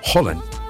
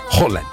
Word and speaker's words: Haaland: Y la doble A es Haaland. Haaland. Así es.